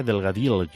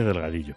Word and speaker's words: Delgadillo: Tenía un amigo que decía Delgadillo. Le dijeron, ya la gente de cierta edad le dijeron que había que pronunciar la L de esa forma, ¿no? Delgadillo. Como hacía Millán, el de Martes y Trece, ¿te acuerdas? Pues no, se dice Delgadillo.